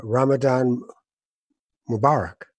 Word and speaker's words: Ramadan Mubarak: So please, today, I guess the best thing to say is Ramadan Mubarak.